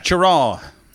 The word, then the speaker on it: Cheraw: Cheraw.